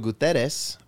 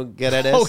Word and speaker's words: Guterres: And so the United Nations chief, Antonio Guterres, I think I'm saying that right. It's not spelled Gutierrez like the Mexican way. It's G-U-T-E-R-R-E-S.